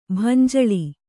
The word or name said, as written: ಭಂಜಳಿ